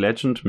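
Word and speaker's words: Legent: Und die haben zusammen einen Whisky gemacht, wie gesagt, Legend mit T am Ende, nicht mit D, deswegen auch die komische Aussprache.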